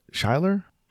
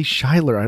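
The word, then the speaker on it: Schuyler: but that it's pronounced Skylar.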